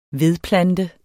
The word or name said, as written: vedplante